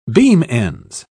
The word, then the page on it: beam-ends: British English